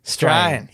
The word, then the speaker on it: Strine: So, because I was saying to, I was speaking to Ellen about this, and I said, I'm not sure what strine is. I don't think I've heard that before. – Yeah. – And then I realized, oh, it's meant to be strine.